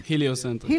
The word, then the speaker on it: הליוסנטריק: הוא נקרא, איך מבטאים את זה? הליוסנטריק? – הליוסנטריק.